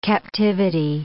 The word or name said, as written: captivity